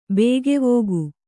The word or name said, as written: ಬಗೆವುಗು